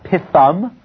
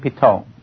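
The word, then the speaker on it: Pithom: Not pithum, but pitom. Pitum became pitom.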